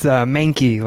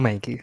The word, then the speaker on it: Mankey: Yeah, it's Mankey, like the Pokemon, or rhymes with cranky, or however you want to go with it. But yeah, Kyle Mankey.